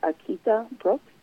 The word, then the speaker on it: Akita Brooks: You can also follow me on Instagram, and that's at Akita Brooks, and that's spelled AKITABROOKS.